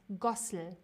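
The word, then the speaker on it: Ghusl: Mit einem scharfen S, Gossel.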